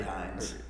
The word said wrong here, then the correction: Hiles: No, see, you said it wrong. It's Hiles.